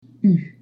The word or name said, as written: ù